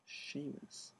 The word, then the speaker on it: Seamus: That's actually how you pronounce it? – Yep. – Seamus.